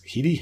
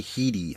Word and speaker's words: Headey: So somebody like Nick Frost being this and Stephen Merchant, even in a small role in Merchant's case, and of course, Lena Headey is Heedy. Heedy, I can never remember how to pronounce it. I think it's actually Heedy. I think I heard that recently.